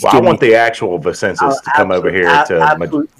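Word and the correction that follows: Vinícius: Vinicius. Vinicius.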